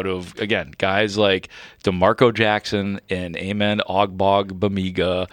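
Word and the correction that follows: Ogbongbemiga: I think it's Ogbong. OGB typically is the G is silent in the first part. – Okay. – I got to look that. – Okay. – Yeah, it's Ogbong Bamiga.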